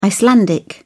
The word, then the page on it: icelandic: British English